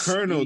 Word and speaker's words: colonel: P-S-E-U. It's like Colonel, dude. Colonel is spelled C-O-L-O-N-E-L, and they want right. Ain't no R in there at all.